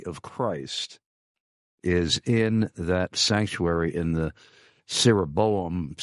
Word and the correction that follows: ciborium: A ciborium, I'm sorry, I mispronounced it. Ciborium.